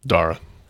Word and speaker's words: Dara: D-A-R-A. – Dara. – Dara? – Yeah. – Dara. So like it's Sarah, Dara. Yeah.